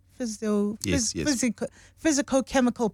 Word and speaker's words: physicochemical: In some studies, I hope I'm saying this correctly, physiochemical, is that correct? Physicochemical properties are usually taken into consideration when it comes to traditional plants and their effects.